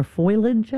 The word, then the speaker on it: foliage: So I've decided to look up the pronunciation this morning, and it's foliage. – Foliage. – So the I is making an E, but foliage definitely means when the leaves are changing, correct?